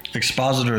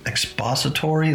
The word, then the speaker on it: Expositor: Expositor is spelled E-X-P-O-S-I-T-O-R, mm-hmm. Expositor. I kept saying expository, like what is that when you put it, when you put a suppository?